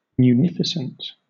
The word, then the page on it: munificent: British English